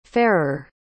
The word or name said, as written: fairer